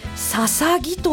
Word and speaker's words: 大角豆: 大きな角の豆と書くので、初見では大角図や大角豆、大津の豆としか読めないのですが、なんとこれでささぎと読むのです。